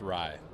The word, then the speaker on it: Raih: It was, it's just Raih, like the bread.